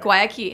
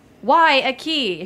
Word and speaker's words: Guayaquil: It's like Why a key if you're at a door, but yeah.